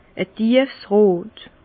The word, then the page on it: e tiefs rot: Bas Rhin; Herrlisheim